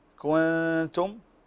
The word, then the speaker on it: كنتم: وكلمة كنتم، هنا انتبه لنطق هذه الكلمة بطريقة صحيحة، عندنا الكاف مضمومة كو، وعندنا النون ساكنة كنتم. طيب، فننتبه أن نحقق هنا الإظهار، نحقق عفوا تحقيق الضم كو، وكذلك الإخفاء كون، ونضم الشفتين مرة أخرى في النطق بالتاء كنتم، فتضم الشفتين عند النطق بالكاف وتأتي بالإخفاء وبعدها تنطق.